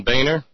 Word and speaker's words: Boehner: I remember sitting down in Germany with my kind and genial hosts and just writing down the letters B-O-E-H-N-E-R and saying, I'm not going to tell you how he insists we pronounce this until after you've, you know, given it some good, you know, given it a good run through the Hochdeutsch. And they looked at me and looked at it and said, Bühne. I said, so closer to Boner than Boehner? Yeah. I said, because he pronounces it Boehner.